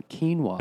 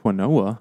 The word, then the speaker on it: quinoa: It's brewed from quinoa. – Unless you pronounce it quinoa, I believe that's quinoa.